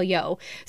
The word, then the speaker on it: Yeoh: And I pronounced it Yao. Now, that could just be me having a weird moment and mispronouncing it, but I was watching some interviews this week with Jamie Lee Curtis and some other people from the cast, and they said Michelle Yeoh.